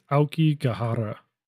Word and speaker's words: Aokigahara: It's Aokigahara. – Aokigahara.